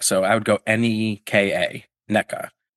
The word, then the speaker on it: Nneka: So if you're pronouncing Nneka, N-E-K-A, that means the letter E in every scenario should be pronounced eh. Across the board, okay.